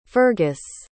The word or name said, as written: fergus